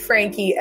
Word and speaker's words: Frankey: Fantastic Frankie, FRANKEY. Yo, hello, got coming to the top. Fantastic Frankie. Frankie is spelled FRANKEY.